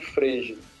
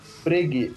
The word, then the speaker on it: Frege: É, eu, esse é o vulgarmente chamado de Frege. – Uhum. – E... – Qual que é a pronúncia correta, você falou? Frege. Frege. Uhum.